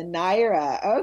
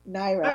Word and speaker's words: Naira: The official currency is the Naira. – That's how you pronounce it, the Naira. Okay, yeah. Naira.